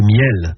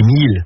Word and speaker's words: miels: Ce sont les miels, qu'on prononce aussi les milles, du côté de Barneville-Cartret.